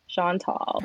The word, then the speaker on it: Chantal: Like Sean is tall. Chantal.